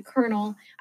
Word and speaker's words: Colonel: Yeah, sorry, Colonel. I don't understand why they don't just spell it Colonel. I don't get it. – Like Colonel, like corn? – Yeah.